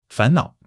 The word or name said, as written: fan2 nao3